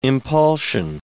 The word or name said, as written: impulsion